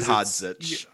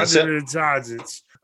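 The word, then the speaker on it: Hodžić: And Ahmed Hodzic. – Yeah, Hodzic.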